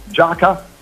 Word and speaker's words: Jaka: Allora, per chi non mi conoscesse, il mio nome è Giaca, si scrive J-A-K-A, ripeto, J-A-K-A, e la mia pagina Facebook è Giaca Official, e quella Instagram è Giaca World, come il mondo di Giaca, Giaca World.